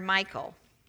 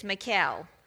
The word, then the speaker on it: Michal: Now, I need to tell you that I grew up calling her Michal. Just so you know, we're talking about the same person. The correct Hebrew pronunciation is Michal.